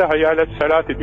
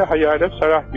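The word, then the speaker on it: hayya ale salati (حي على الصلاة): Ama birinci de hayya ale salati diyoruz. İkinci de hayya ale salah diyoruz. Efendim, bu neden? İşte bu T harfi geçildiği zaman T okunur, durulduğu zaman H okunur da ondan.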